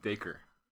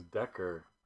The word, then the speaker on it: Dacre: I'm pretty sure it's pronounced Dacre. – Dacre Stoker. – Dacre Stoker. – Oh dear. – I know. – I was thinking it was Decker.